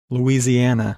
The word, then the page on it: Lousiana: English